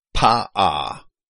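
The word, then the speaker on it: pa'ah: P-A apostrophe A-H. Pa-a. Pa-a. However that apostrophe was pronounced because it's treated like a stop in modern Hebrew.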